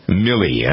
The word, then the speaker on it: Milley: All right, so anyway, the commander at Fort Hood is a gentleman named Mark Milley. I don't think it's Miley because there are two L's in it. I think it's pronounced Milley. Is it not? – Milley is, that's what I heard.